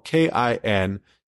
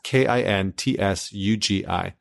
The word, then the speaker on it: kintsugi: And what you get, and you can look it up, it's spelled kintsugi. Again, it's kintsugi.